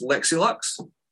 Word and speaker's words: Lexi Luxx: It's Lexi Luxx.